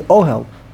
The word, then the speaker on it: ohel: In Jewish language, by the way, the word ohel is a yotze min haklal. It's the only one that you actually pronounce it two syllables before the end. Ohel. Ohelo. It's ohelo. – Ohel, okay. – If it's three syllables, you just go back one. But here you go back two.